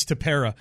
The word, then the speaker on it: Tepera: Listen, this postponement of the game yesterday should not absolve Ryan Tepera, or however we pronounce his freaking name. It's Tepera. It should not let us forget Ryan Tepera and what he said about the Astros earlier this week.